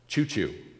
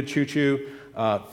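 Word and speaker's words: Ciuciu: It's Asherita Chuchu. Okay? No joke, just like the train. Asherita Chuchu, very excellent Advent devotional.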